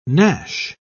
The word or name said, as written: gnash